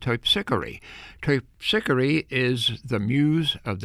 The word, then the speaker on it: Terpsichore: However, we say, I think, Terpsichore as a different pronunciation here in New Orleans. Terpsichore is the correct pronunciation of the muse of the dance,